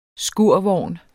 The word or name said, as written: skurvogn